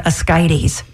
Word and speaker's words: ascites: It was an infection in the fluid in the abdomen, and I had something called ascites, which is spelled A-S-C-I-T-E-S. I'd never heard that term before. In fact, I was calling it ascites, but it's ascites.